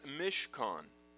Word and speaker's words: Mishkan: Pronounced in Hebrew, if you like this, is Mishkan. Mishkan.